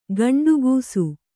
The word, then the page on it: ಗಂಡುಗೂಸು: gaṇḍu gūsu